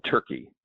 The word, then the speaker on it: Turki: called Turkey, which is spelled T-U-R-K-I, Turkey.